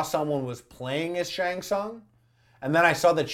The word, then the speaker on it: Tsung: you know that T got pronounced yeah. hard. – Tsung, yeah.